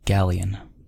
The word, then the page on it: galleon: American English